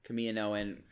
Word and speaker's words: Kaminoan: Kaminoan. – That's the correct pronunciation. Yeah, Kaminoan.